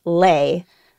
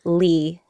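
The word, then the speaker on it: Le: So the traditional pronunciation of the last name L-E mm-hmm. is Lay, but I'm used to calling myself Lee, L-E, yeah. because growing up, if you come from a Vietnamese American family or like an immigrant family, you have like an American way of saying your name right. – and a Vietnamese way of saying your name. So yeah, I just grew up feeling like Lee was my last name.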